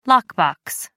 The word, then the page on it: lockbox: American English